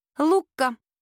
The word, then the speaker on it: Лукка: «Лукка». – «Лукка с ударением на «а»?» «С ударением на «у».